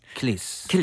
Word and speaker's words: Achilles: A가 발음이 잘안 들려요. 모음이라서. 그래서 킬레스. – 킬리스. – 킬리스. 이렇게 들립니다.